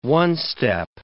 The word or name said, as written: one-step